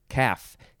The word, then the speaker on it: calf: All right, next is calf, C-A-L-F. You don't pronounce the L. It does look like people say calf or cough or cafe. Who says cafe for this word?